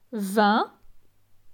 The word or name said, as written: 20